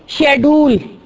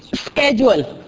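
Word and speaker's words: schedule: اب آپ دیکھیں شیڈول، شیڈول یہ لفظ ہے اسکیجول، تو اسکیجول کون بولتا ہے؟ امریکن. اور شیڈول کون بولتا ہے؟ برطانیہ.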